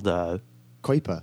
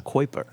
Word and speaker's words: Kuiper: Kuiper. – Kuiper. – Yeah. Okay. I've learned it's the correct pronunciation.